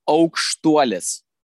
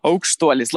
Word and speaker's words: Aukstuolis: Aukstuolis. – All right. I was close, but I didn't nail it. Aukstuolis. – Aukstuolis, like an oak tree. Aukstuolis.